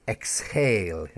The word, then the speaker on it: exhale: Okay, but there is an exception, and the word exhale. Yeah, when you're breathing in, exhale out. Breathe in, exhale out. E-X-H-A-L-E. And in this sense, the H is stressed.